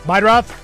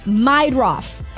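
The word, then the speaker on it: Meidroth: Chase Murda? The White Sox guy? – Meidroff. – How do you say that? – Meidroff.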